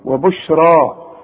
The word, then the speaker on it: وبشرى: وبشرى عند الياء بتتحول إلى ألف عند النطق، وبشرى تكتب بالياء، وبشرى للمؤمنين.